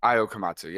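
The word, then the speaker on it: Ayao Komatsu: He gets replaced by, feel free to correct me on the pronunciation, Ayo Komatsu? – Ayo Komatsu, yeah.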